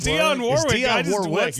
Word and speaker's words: Dionne Warwick: Is it Warwick? – It's Dionne Warwick. You're right.